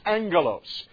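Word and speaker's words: angelos (ἄγγελος): The Greek word here literally is the word angelos. That's how it is pronounced, angelos.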